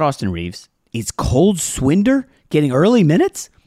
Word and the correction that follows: Swider: did I say his name wrong? – He said Swinder. – Swider.